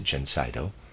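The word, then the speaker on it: Gencydo: This remedy, which we manufacture under the name Gencido, and I'll spell that, it's G-E-N-C-Y-D-O, I'm going to pronounce it Gencido, is effective in the great majority of hay fever cases.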